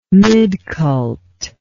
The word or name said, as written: midcult